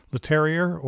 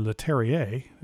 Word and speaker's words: Leterrier: I mean, you got Louis Leterrier or Leterrier, depending upon how you want to pronounce his last name.